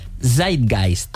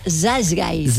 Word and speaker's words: Zeitgeist: pero que se llama Zeitgeist. – ¡Ay, me encanta! Zeitgeist. – Zeitgeist.